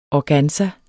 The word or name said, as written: organza